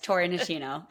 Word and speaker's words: Tori Nishino: Yes, Tori Nishino.